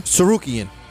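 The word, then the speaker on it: Tsarukyan: Main event of the evening, Arman Tarkanian. How you say it? Tarkanian? – Sarukian. – That's right.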